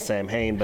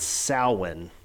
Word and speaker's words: Samhain: Everybody pronounces it Samhain, but it's weird. It's Samhain.